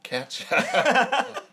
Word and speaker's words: Katya: Katya. – Katya. – She'll catch ya.